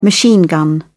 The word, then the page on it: machine gun: British English